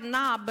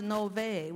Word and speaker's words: Nob: Now that word, it looks like Nob, but it's actually pronounced Nove with a V.